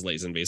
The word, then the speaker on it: Lazenby: And his comment was, funny how one of you pronounced it Lazenby and the other Lazenby. – It's so, and in fact, halfway through that episode, I change it. And it's because I've heard both. Yes. I have heard both. I believe he says, like George Lazenby himself says Lazenby. So I'm inclined to go with his pronunciation.